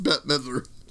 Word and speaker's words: Bette Midler: It's pronounced Bette Midler.